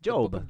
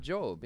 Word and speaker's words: Job: Job? How do you pronounce it? – Job. – Job, yeah. – J-O-B, right?